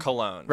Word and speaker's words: cologne: You said colon. You meant cologne? – I meant cologne.